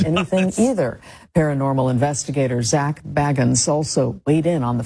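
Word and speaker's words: Bagans: It's unclear how to say his name. He says Baggins. Everybody else reads it as Baggins. I think to make him sound a little more ridiculous.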